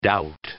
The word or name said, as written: doubt